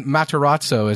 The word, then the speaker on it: Matarazzo: I remember I tweeted about Stranger Things, and I tweeted about specifically Dustin, Galen Matarazzo. Is that how you say it? 2 Z's, 1 R? – I believe that's right.